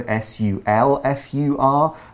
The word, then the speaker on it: sulfur: But sulfur, sulfur, it's spelled it's, it's spelled differently. It's pronounced the same way.